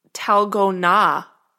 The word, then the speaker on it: Dalgona: Which is apparently pronounced Talgona, if you do it correctly, at least according to this. – Well, that one, so I would have gotten that one wrong. – Yeah, I know, me too. Yeah, some of these I also, like, I was like, oh, okay. It's Talgona is the correct pronunciation.